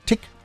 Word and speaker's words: Tich: T-I-C-H wird Tick ausgesprochen. Ja, so wie Tick-Tack, Tick-Tock, Tack, Tick-Tack, Tick.